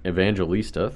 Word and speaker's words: Evangelista: Philip Forsberg, Ryan O'Reilly, and Luke Evangelista. – Evangelista, thank you, is their top line. – If it's not pronounced Evangelista, he needs to get on it.